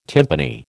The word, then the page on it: tympani: American English